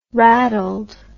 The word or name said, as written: rattled